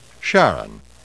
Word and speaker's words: Charon: According to Christie, he named the moon Charon, pronounced Charon, with his wife Char in mind.